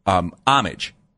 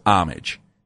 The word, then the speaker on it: Homage: Now, some people don't know how to pronounce their name. They call themselves Homage, but you look at the word and I think most people pronounce it homage. – I do that, yeah. – Okay. But the company itself calls themselves Homage, so I'm going to call them Homage for the sake of this story.